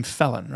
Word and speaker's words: Phelan: I believe you pronounce his name Felon, right? – Maybe so. Felon, I think, is what Phelan. He prefers. – H-L-E-A-N. Looks like Felon to me.